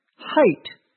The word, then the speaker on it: height: Notice that we also use the same pronunciation for the past tense of the verb to eat, which is spelled A-T-E. But the number 8 is spelled differently, even though it is pronounced the same. The next word will pronounce is spelled H-E-I-G-H-T. Height is how we pronounce that, even though it has the same spelling except for the letter H in front of it. We don't pronounce it like the word hate, H-A-T-E, but rather height. The final word is spelled W-E-I-G-H-T.